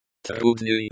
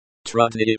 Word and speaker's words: Трудный: Трудный, the y at the end of the word is pronounced like the y in yes, and the u is pronounced like the u in moon.